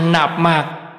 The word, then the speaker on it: Nam Mô: Chỉ có trong Phật hiệu thì hai chữ Nam Mô phải đọc là Nạp Mạc.